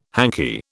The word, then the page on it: hanky: British English